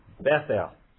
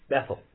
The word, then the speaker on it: Bethel: And as I intimated in the reading of our text, house of God is pronounced in the Hebrew Bethel. Bethel. The word Beth in Hebrew means house. And El, you may already know this one, means God. So Bethel means house of God.